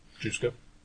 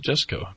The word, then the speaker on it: Jusko: Jusko? – He pronounces it Jesko, apparently.